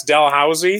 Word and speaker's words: Dalhousie: Is it Dalhousie or Dalhousie? – Oh, that's or? – Where I did my master's. That's Dalhousie in the East Coast of Canada. – Dalhousie.